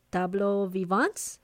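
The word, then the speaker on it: tableaux vivants: And you mentioned, tú dijiste in the beginning of the interview that you're going to incorporate something called tableau vivants. Did I say that correctly? – Yes.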